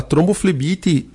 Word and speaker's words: tromboflebite: Gostaria de perguntar qual o risco para quem desenvolveu uma tromboflerite, trombobite, eu acho que é, né? Flebite, doutor. O senhor depois explica qual é que é a pronúncia certa na perna e qual também o tratamento para essa doença.